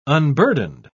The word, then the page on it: unburdened: British English